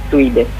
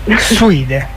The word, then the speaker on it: Suidae: L'associazione Terra Madre, in collegamento con Branco Suidae, si legge così? – Suide. – Suide, perché sarebbe? Io sono molto ignorante. – Beh, Suide è la famiglia dei cinghiali.